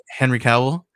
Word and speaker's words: Henry Cavill: Henry Cavill.